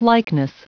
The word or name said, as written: likeness